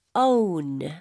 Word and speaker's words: own: 소유하다라고 할 때 own. – own. – own. – 네. 이 O-W-N 발음도 원이 아니라 오운에 가깝다고 볼 수 있겠고요.